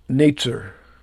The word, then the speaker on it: Netzer: The word branch is pronounced Netzer, with a Z kind of sound. It's spelled with an S.